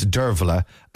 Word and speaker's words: Dearbhaile: And I typed in how to pronounce Dervla, and it's that spelling, D-E-A-R-B-H-A-I-L-E.